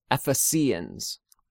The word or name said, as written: Ephesians